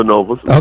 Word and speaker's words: Synovus: You're a key figure in the world of finance, including being the executive VP of Synovus Financial Corporation. Did I say that right? Is that Synovus? – Yes, yeah, Synovus.